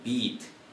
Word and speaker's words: beat: 근데 주의할 거는 우리나라 사람들이 영어 발음을 할 때 장음을 잘 신경을 안 쓰세요. 그래서 이게 비슷한 단어가 bit가 있죠. – bit. – 네. 근데 이거는 beat 길죠.